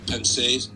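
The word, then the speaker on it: Pensées: It's got a French pensée, P-E-N-S-E-E-S.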